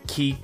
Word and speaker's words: Chi: We'll see you on the next episode of X brackets Chi pronounced Key close brackets hyphen Blade over 2 colon punished audio.